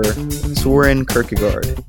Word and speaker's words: Søren Kierkegaard: Welcome to episode 13 on the life and ideas of Danish philosopher Søren Kierkegaard.